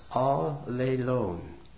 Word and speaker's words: allelon: It's spelled A-L-L-E-L-O-N, Strong's number 240, and it's pronounced all-le-lone. It almost sounds like all alone, but it means just the opposite.